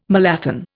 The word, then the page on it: malathion: Indian